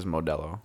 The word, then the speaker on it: Modelo: Yeah, you are saying it right though. It is Modelo.